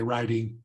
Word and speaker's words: writing: So in American English, we'll usually make the T in writing a D. We'll make it a flap. Say writing.